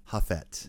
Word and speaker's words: Jafet: Imagine a H instead of a J. So imagine it's spelled H-A-F-E-T. – Jafet. – Yeah. – All right, Jafet.